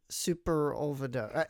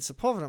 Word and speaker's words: Sepulveda: I thought it was Superolveda. I don't know. I was dumb. I couldn't figure out how to say it. And then somebody said Sepulveda. I'm like, oh, that's how you say it.